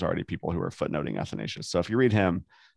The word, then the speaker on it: Athanasius: Athanasius. That's the way all the British patristic scholars do it.